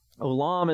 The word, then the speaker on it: olam: If you look at the Hebrew word here, olam is how you would pronounce that in the English, if you were to transliterate it.